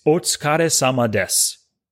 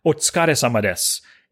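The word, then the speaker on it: otsukare sama desu (お疲れ様です): but it's otsukare sama desu. Once again, otsukare sama desu. And that's spelled, it's three different words, otsukare, O-T-S-U-K-A-R-E, sama, S-A-M-A, Des, D-S-U.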